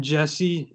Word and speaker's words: Jesse: Of course, uh, so Cinquini is spelled C I N Q U I N I, and then Jesse is J E S S E.